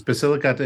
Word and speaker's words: Basilicata: Yes, it's Basilicata and Puglia.